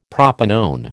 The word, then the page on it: propanone: American English